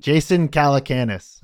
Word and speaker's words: Jason Calacanis: Jason Calacanis.